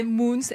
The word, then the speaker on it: Moens: Et Moons, c'est Moons, M-O-E-N-S.